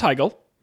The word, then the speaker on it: Heigl: No, it's Heigl.